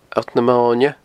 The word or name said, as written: Allt na Moine